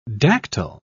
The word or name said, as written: dactyl